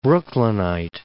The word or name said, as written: Brooklynite